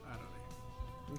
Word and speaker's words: Arale: Yeah, that's how you say it, Arale.